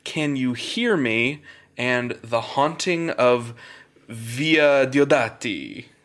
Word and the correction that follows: Villa Diodati: or however you pronounce it. Villa, right? – Villa Diodati.